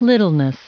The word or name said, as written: littleness